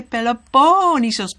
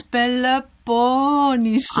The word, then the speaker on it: Peloponnesos: So I say Peloponnesos because that is the way one of the Greeks told me you should pronounce it. She said, you know, when I was in primary school, the teacher taught us the pronunciation, and he said, Peloponnesos. – Okay. – So now it's, Karen, it's Peloponnesos.